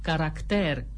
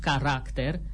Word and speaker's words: caracter: după ce repetă asemeni altor persoane știutoare că se spune corect caracter, nu caracter, rucsac, nu rucsac, simbol, nu simbol, Oana Nicolae dă lovitura de grație.